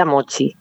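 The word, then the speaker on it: Mocchi: Mochi se escribió con dos Cs, como cuando dices Mochi, pero en italiano, pero no, se pronuncia Mochi. Aunque, bueno, en Uruguay vienen de procedencia italiana mucho, pero se pronuncia Mochi, ¿vale?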